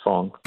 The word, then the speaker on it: Fang: It's F-A-N-G. But pronounce Fong.